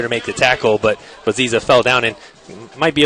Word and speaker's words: Nziza: Josh Ziza, silent N, by the way. – Okay. Good to know. – Yes, that's exactly right. It's spelled N-Z-I-Z-A.